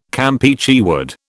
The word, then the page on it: Campeachy wood: British English